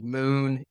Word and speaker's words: MXN: The brand that we'll focus on here today is called Moon. It's spelled actually M-X-N.